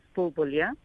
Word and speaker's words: Bulbulia: Okay, so I'm going to start with my name, as you asked whether or not it was correctly pronounced, Firdoze Bulbulia.